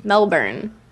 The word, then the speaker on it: Melbourne: It's Melbourne. – Melbourne. – It's like B E N. Just pretend it's M E L B E N.